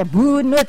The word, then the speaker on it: Bunut: Ini kliknya Buenoet, dibacanya Bunut. Okay. Ejaan yang dulu ya.